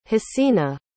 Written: hasina

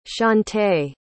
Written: shante

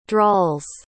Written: drawls

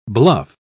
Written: bluff

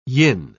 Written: yin

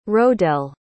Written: rodel

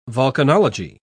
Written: volcanology

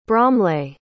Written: bromleigh

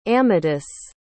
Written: amatus

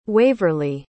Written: waverly

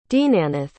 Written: deenanath

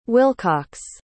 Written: wilcox

